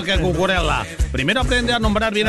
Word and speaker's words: Cucurella: No, es Cucurella, no Cucurella.